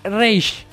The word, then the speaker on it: Rage: Bueno, todas las personas que lo escucho decir, dicen, no, yo escucho Rachel Machine. Nadie sabe pronunciar la banda. A ver que la pronuncia alguno de ustedes. – Rachel Machine. – Palabra por palabra. – Rachel. – Rachel. – Again. – No, es Rage. – Rage. – Porque es Rage.